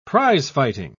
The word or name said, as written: prizefighting